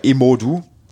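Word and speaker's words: Imoudu: weil sonst hast du mit Denise Imudu eine super Zuspielerin, Imodu, wir haben es richtig gelernt von der Aussprache, Betonung auf dem O übrigens,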